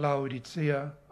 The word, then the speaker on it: Laodikea: Zum Beispiel Laodikea, manchmal Laodicea ausgesprochen, und Hierapolis oder heilige Stadt, Hierapolis.